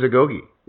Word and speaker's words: Agoge: Let's just start with, so what is Agoge? First of all, am I pronouncing it correctly? – You are pronouncing it correctly.